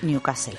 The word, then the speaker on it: Newcastle: Hazme caso, se dice Newcastle.